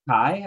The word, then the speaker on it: Kei: Kai, I think. K-E-I is how it's spelled.